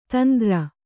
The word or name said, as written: ತಂದ್ರ